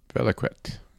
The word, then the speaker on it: Valiquette: If I'm pronouncing it correctly, it's V-A-L-I-Q-U-E-T-T-E. – Valiquette.